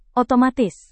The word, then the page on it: otomatis: Indonesian